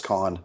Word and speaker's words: Kahan: Don't pronounce his name that way. He'll get upset. – Kahan? – No, it's just Kahn.